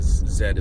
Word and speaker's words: Zed: Because Zed is the proper pronunciation. – That's right. – Of the letter Zed.